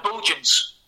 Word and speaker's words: Bultjens: Just on the silent T, so Bultjens.